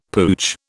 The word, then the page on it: pooch: British English